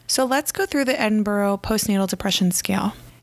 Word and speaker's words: Edinburgh: Now, this scale is from Great Britain, so it's pronounced like Edinburgh versus Edinburgh. So for any of my Pittsburgh people, this is pronounced like the town of Edinburgh in PA, which borough is actually spelled borough, unlike this one.